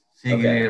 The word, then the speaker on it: Sigiriya: Is it Sigiriya? – Sigiriya okay. Rock.